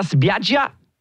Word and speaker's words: spiaggia: Andiamo alla spiaggia. – Ja. – Spricht man das so aus? Spiaggia? – Ja, ja.